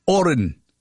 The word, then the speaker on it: Horan: Oh, yes, he prefers the silent H, Oren. H-O-R-A-N, Niall Oren.